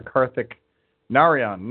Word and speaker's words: Karthik Narayan: We're moving on with uh, senior manager Karthik Narayan.